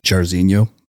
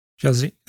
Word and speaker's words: Jairzinho: Can you educate us on Jairzinho? – Jairzinho, you nailed it.